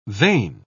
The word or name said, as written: vain